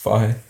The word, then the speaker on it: Fage: So what do you think about Fahe? – I've not heard that. I don't even know what it is. – F-A-G-E. – Is that how it's pronounced? – Fahe, yeah.